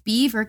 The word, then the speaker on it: Belvoir: And explains that when her younger sister was 3, she was in the car and they drove past Beaver Castle, spelled Belvoir, Belvoir Castle, but she says it's pronounced Beaver.